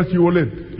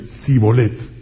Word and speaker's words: Shibolet: Porque los de Efraín no podían pronunciar la palabra con la S y con la H, Shibolet, no podían. Cuando decían: ah, dilo ahora Shibolet, ellos decían Sibolet.